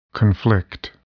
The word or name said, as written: conflict